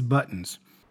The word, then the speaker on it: button: First of all, it's important to point out how he pronounces the word buttons. You see, he says button. – Buttons. – Not button. All right. So there is a bit of a glottal sound there. Button, button. All right. That's how he says it.